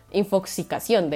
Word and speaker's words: infoxicación: infoxificación, como intoxicación, pero con información, infoxificación. infoxicación, de hecho, creo que lo dije mal, infoxicación, intoxicación, infoxicación.